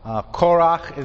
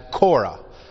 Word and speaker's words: Korach: Korach is a proper name, usually translated in English without that hard H sound, simply as Korah. But Korach is his name. Korah is the English kind of pronunciation for it.